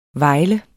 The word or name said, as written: vejle